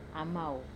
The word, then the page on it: amau: Wamesa